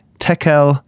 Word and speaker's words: Tekel: Could you explain the, quote, writing on the wall and the words, I'm going to mess up this pronunciation, but Mene, Tekel, and Perez, what does it mean? – You know, that was good pronunciation.